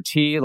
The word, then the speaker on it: Ti: Andrew T, last name is spelled T-I on Twitter and Instagram.